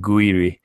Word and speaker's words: Gouiri: Guiri is a, spelled G-O-U-I-R-I, if you wanted to know for a better pronunciation, but he's a 17-year-old that a lot of people are very excited about if he maybe gets a chance.